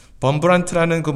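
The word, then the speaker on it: Wurmbrand: 제가 한 설교에서 리차드 원브란트라고 읽는 게 더 나을 것 같네요. 범브란트라는 그 목사님을 한번 말씀드린 적이 있습니다.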